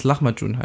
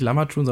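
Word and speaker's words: Lahmacun: ich habe auch irgendwann mal gelernt, dass es Lachmatun heißt. Aber ich glaube, es ist auch sehr falsch ausgesprochen. Nicht Lamatun, sondern.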